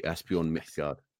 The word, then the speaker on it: Asbjørn Midgard: Asbjørn Midgard. – I don't know if Asbjørn is listening in, but I'll definitely send him the link to this one. He'll appreciate that. – Hello, Asbjørn. I'm trying to get your name right, but I'm English. And that was very well. That is a lot better than I actually heard. You just did it right.